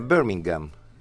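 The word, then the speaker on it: Birmingham: Όπως δεν προφέρεται το Χου και στο Μπέρμιγχαμ που λέμε εμείς. Είναι Μπέρμιγχαμ. – Γκάμ, ναι. – Δεν υπάρχει Χου στα αγγλικά.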